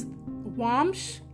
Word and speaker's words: Womsh: So the first resource for a shoe brand is Womsh, and it's spelled W-O-M-S-H.